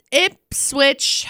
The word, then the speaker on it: Ipswich: Okay, so Ipswich Hospital, that's how you say it.